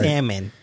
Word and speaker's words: salmon: You can eat turkey, chicken, and salmon, but I can't eat tomatoes, potatoes. Hey, the language police gonna come and get you. They're gonna be like, the L is silent.